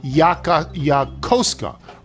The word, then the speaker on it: Yokosuka: I live in the Kanagawa Prefecture in a medium-sized town named Yokosuka. Resist the urge to pronounce it as it's spelled.